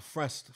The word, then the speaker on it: Frest: Oh, Frest. – Frest. – Like, blessed, but, you know, F-R.